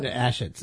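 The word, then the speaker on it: Achatz: Grant Ashatz. – Grant Ashatz? What? – Ashatz.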